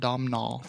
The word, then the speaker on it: Domhnall: Oh, I keep on putting the H instead of Hall. It's Nall. Domhnall. – Silent H.